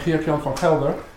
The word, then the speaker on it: Geert Jan van Gelder: I'm Geert Jan van Gelder, which should be easily pronounceable to people who know Arabic, and others can call me, I also listen to the name of Gerard.